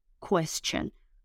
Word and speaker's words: question: La Q se pronuncia como K, ¿verdad? Q, quest, quest. Bien. Luego la T no suena como T, no es cuestión, sino question, question.